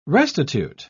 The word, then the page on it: restitute: British English